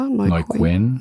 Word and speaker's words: Neuquén: Patagonien gehört zu den südlichsten Weinregionen der Welt und umfasst die Provinzen Neuquén, halb wird es, glaube ich, ausgesprochen, oder? – Neuquén. – Neuquén und Rio Negro.